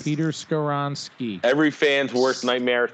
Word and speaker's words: Skoronski: Skoronski. Yeah, Skoronski. Yep, that's how you pronounce that, I think.